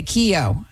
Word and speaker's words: Keo: Keo, okay, it's like a key with an O on it. – Okay, Keo, thanks for the call.